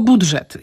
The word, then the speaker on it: budżet: Wymawiamy tylko budżet. Inaczej mówiąc, nie mówimy budżet, tylko budżet. A ewentualnie możemy mniej starannie powiedzieć budżet, czyli tam jest dżyży, ale najpoprawniej jest dyży, czyli budżet.